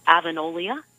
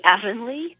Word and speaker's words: Avonlea: I had always pronounced an Avonolia, in spite of the fact that there is no second O in that word. It should be Avonlea, but I can only admit that now as a 51-year-old.